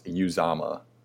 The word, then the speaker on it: Uzomah: Another one of your calls that I absolutely love is for CJ Uzomah, I believe is how you're going to say. – I actually looked up his pronunciation today when I was doing some stuff. It's Uzomah.